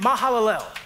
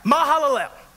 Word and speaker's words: Mahalalel: He named him Mahalalel. If you think I did take some Hebrew classes to learn this, I did look up on YouTube how to say this guy's name, okay? Mahalalel.